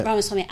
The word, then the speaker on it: Vivek Ramaswamy: Ramaswamy.